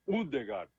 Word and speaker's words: Ødegaard: Aí é Ødegaard. – Ødegaard. – Porque o O, aqui tem a diferença, né? O O cortado no meio, né? Aí ele tem esse som de U, U. Assim, a pronúncia.